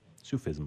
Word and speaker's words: Sufism: Sufism? – Sufism.